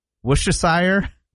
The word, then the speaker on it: Worcestershire: So you don't say the R, you don't say Worcestershire? – No. – Worcestershire. – Worcester. – Worcestershire. – Yeah, I'm going to go to Worcestershire.